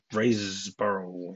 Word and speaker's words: Riseborough: Okay, that was that's a movie that Andrea Riseborough, I think that's how you pronounce her last name. Yes. Okay.